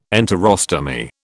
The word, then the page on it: enterostomy: British English